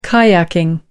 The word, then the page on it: kayaking: American English